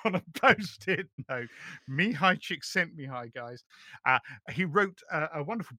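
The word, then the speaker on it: Mihaly Csikszentmihalyi: I'm brandishing a book here, Finding Flow by Mihai Csikszentmihalyi.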